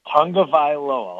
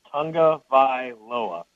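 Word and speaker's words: Tagovailoa: It's pronounced, it's Tungavailoa. I'll say that, Tungavailoa.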